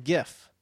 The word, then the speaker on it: GIF: However, we've been saying GIF, but the creator of this format says it's pronounced GIF, as in the peanut butter. Right. He said it's a soft G, pronounced GIF. End of story.